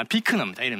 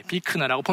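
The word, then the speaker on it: Buechner: 그리고 이제 이 부분은 이제 이분은 프리드릭 뷰크너라고 하는 아 비크너입니다. 이름은 이름을 발음하기 되게 어려운 분인데 비크너라고 본인이 주장합니다.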